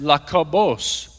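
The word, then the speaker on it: Lakobos (Ἰάκωβος): In Greek, his name is pronounced Lakobos, or it could actually be translated as Jacob.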